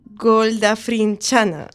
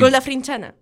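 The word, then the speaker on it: Golgafrinchana: Mientras tanto, Arthur y Ford acaban en una nave aleatoria goldafrinchana. Dios. – Decidlo bien a la primera. – Y... goldafrinchana. Toma. – No puede ser. – A la segunda lo he dicho bien.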